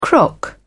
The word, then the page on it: crock: British English